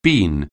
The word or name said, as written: bean